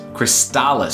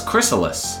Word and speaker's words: Crystalis: So you pronounce the T. I do. Okay. So. Oh, you don't pronounce the T? No, it's chrysalis. – No, I thought you no, just no, pronounced silent chrysalis. No, no, chrysalis. No, chrysalis is a different word. There is no T in the word chrysalis.